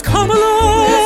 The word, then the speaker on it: Kamala: You dare pronounce her name Kamala. It's Kamala.